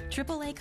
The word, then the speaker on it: AAA: AAA covers members, not cars.